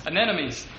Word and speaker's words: anemones: I asked how to say this, and I'm going to mess it up again. How did you say that? – Anemones.